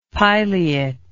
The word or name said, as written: pileate